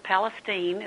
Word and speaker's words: Palestine: Well, actually, it's Palestine. It's a different pronunciation in East Texas.